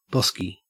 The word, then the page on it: bosky: Australian English